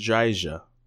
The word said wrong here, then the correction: Dryja: How would you say that? – Yeah, Tadge Dreja, Tadge Dreja, Dryja. Tad That's a beast of a last name.